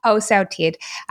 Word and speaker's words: Wholesale TED: And I understand in my New Zealand accent, that sounds like I'm saying Wholesale TED, as in T-I-D. In New Zealand, we don't really have the letter E. We basically pronounce it as the letter I, but it is Wholesale TED.